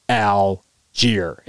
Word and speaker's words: Allgeier: So not great numbers, but numbers that won the game because Tyler Allgeier. And yes, I just made his name three syllables long because this man was an absolute dude.